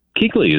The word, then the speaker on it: Kuechly: No, I'm saying it's spelled Kuechly, but it's pronounced Kuechly. You had it right.